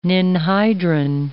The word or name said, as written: ninhydrin